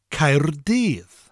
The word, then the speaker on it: Caerdydd: Spelled C-A-E-R-D-Y-D-D. The first syllable ends with a rolling R, Caer, and the double D at the end of the second syllable gives us a th sound, dydd. So the full word would be Caerdydd.